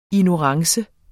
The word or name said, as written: ignorance